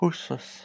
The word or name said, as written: huswes